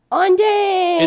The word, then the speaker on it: An Dang: The special guest is Ondang. – It's me. Surprise. My name is Ondang. It's spelled A-N, but it's pronounced on, like when you turn on the lights.